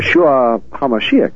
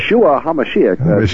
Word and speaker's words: Yeshua HaMashiach: Yeshua HaMashiach. – Right. Okay, very good. I never can quite pronounce that. Yeshua. – Yeshua HaMashiach.